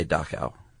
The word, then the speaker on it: Dachau: Well, people just say Dachau. – Okay. – Yeah. You were sort of overdoing it a little bit.